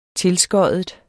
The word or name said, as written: tilskoddet